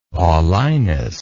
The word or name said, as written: Paulinus